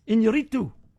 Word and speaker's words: Iñárritu: Iñárritu. That's how you say his name.